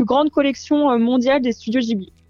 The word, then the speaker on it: Ghibli: Alors, c'est Ghibli Fan France, donc Ghibli, comme ça se prononce, Ghibli, .fan.france, comme la France, FRANCE.